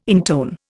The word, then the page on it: intone: Indian